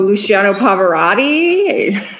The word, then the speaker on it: Luciano Pavarotti: So Luciano Pavarotti? Did I say that correctly? – You did, yeah.